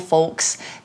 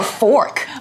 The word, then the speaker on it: folk: Just make sure you pronounce it very carefully so it doesn't sound like a, you know, a bad word. Oh, or fork. or fork, yeah. That's right, it's folk. Folk.